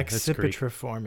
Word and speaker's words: Accipitriformes: Oh, it's a C, soft C, and a hard C. – I know they just love it. The the that Greek. – Yeah, Accipitriformes.